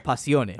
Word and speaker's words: Passione: But it's with two N's and an E at the end. So okay. – Passione. – Okay. – That's how they say it in the song.